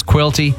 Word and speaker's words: Quilty: Yes, my last name is Quilty. Unfortunately, it rhymes with both filthy and guilty, which is why I must avoid getting involved in some sort of scandal.